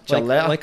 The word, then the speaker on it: Ciarletta: How do you say it? Ciarletta? – Ciarletta. Ciarletta. Like a CH, man. Like CH, Ciarletta. – Aye, the silent H.